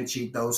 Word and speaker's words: Chitho: Tell them Chitho said it on IG. You could just type in Chitho, C-H-I-T-H-O. If you don't say the other H, you're not saying it right. You could catch me on Twitter, at @RatChitho, R-A-T-C-H-E-T. No, R-A-T-C-H-I-T-H-O.